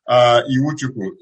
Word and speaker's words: Iwuchukwu: Iwuchukwu is spelled I-W-E-L. The spelling is I-W-U-C-H-U-K-W-U.